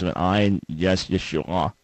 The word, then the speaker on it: Yeshua: And just to set the record straight, the original way of pronouncing the name of Jesus would have been Yeshua, not Yahshua, but Yeshua.